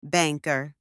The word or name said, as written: banker